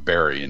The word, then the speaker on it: Barre: Yes, B-A-R-R-E, pronounced Barry in Massachusetts.